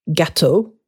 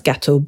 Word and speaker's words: gâteau: So can you see how this is different to, I don't know, here's a word, gâteau, and then you're sort of saying, right, this is one of the key words we need to learn today, un gâteau, and the E-A-U is pronounced O, and that's it.